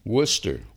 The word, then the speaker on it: Worcester: Worcester. – Worcester. – Yeah, wow. – It's W-O-R-C-E.